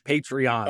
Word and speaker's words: Patreon: And I am saying, I don't have a Patron. I don't want a Patron. It's Patreon. – Oh, Patreon.